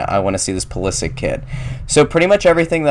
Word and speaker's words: Pulisic: Christian Pulisic, the kid from Hershey, if you didn't hear, he plays for Borussia Dortmund in the Bundesliga in Germany.